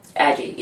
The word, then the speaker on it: Addyi: Is it pronounced Adyee? – Aggie, yeah. – Aggie. – Yeah. – If you want to look that up. ADDYI, I believe. – YI or YE, or one of those.